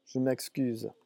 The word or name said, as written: je m'excuse